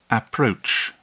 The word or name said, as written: approach